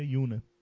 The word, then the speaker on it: Yuna: Yuna. Se pronuncia Yuna.